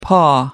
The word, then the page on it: paw: American English